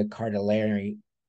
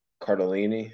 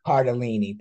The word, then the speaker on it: Cardellini: And then finally, Christina Applegate and Linda Cardellini. I said that wrong. I'm trying to say from memory. Linda Cardellini. Yeah. – Cardellini, thank you.